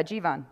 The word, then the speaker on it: Jivan: Did I say it right? – Yeah, I think so. Yeah, Jivan. – Jivan. – So, yeah. – You're like Steven, but Jivan.